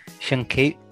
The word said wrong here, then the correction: Sienkiewicz: Não, não consegui. Como que ela disse? – Sankevich.